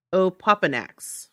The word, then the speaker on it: Opoponax: The Opoponix? Am I saying that right? The feather? – Opoponax.